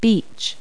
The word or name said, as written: beach